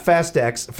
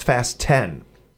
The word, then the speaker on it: Fast X: It's not Fast X. It's pronounced Fast 10, not Fast X.